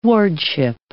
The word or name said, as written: wardship